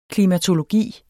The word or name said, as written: klimatologi